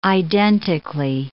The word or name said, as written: identically